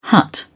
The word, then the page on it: hut: British English